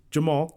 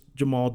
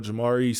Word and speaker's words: Jemarl: I do want Lobo fans to know when they see the spelling of your name, it is Jemarl. – Yes. – As a Jeff with a G that gets mispronounced all the time, I understand sometimes that people don't want it to be just the most common pronunciation, but it is just Jemarl. Do you get some weird pronunciations of it sometimes? – For sure. Jemarl, Jamari sometimes, if they don't know it's an L at the end, I get all kind of stuff.